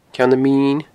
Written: Ceann Min

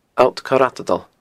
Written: Allt Coir a Tadail

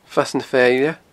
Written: Fasadh na Feile

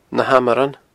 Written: nahamaran

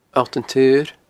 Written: Allt an t-Saoir

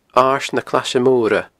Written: Aird na Claise Moire

